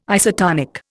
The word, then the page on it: isotonic: Indian